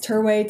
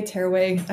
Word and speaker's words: Terway: It's Terway, Terway. I asked my husband, he's like, either one works.